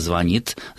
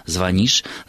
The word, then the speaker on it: звонит: Но до тех пор, пока есть люди, которые знают, что только звонит, звонишь, звонят, ударение в этих формах этого слова ставится на окончание, мы должны придерживаться этой точки зрения.